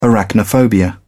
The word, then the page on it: arachnophobia: British English